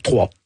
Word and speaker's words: Troyes: On dit Troyes en France? Comment on prononce? – Troyes. – Ben voilà, ça s'écrit YES en France.